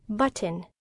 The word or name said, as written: button